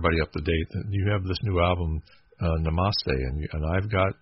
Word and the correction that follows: Namaste: Namaste. – Namaste. Yeah. So you know what? I always put the emphasis on the wrong syllable.